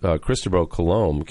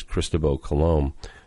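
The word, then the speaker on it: Cristóbal Colón: And after Cristobal Colón came, that's Christopher Columbus, by the way, the European pronunciation, Spanish pronunciation is Cristobal Colón, came to the New World and discovered the New World.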